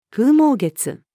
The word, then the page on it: 風待月: female